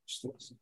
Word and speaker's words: Schlesinger: So Adam Schles- how do you say his last name? – Schlesinger.